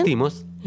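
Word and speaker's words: Lanthimos: Lantimos.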